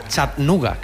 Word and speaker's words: Chattanooga: Vuelo no directo, pero si Dios quiere, por la noche estaremos en Chattanooga, que me dicen que se pronuncia así.